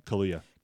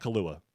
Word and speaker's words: Kaluuya: How do you say his name? – Kaluuya. – Kaluuya. – Not Kalua.